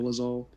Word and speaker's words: Dolezal: Dolezal, let me know if I pronounced it wrong. – Dolezal.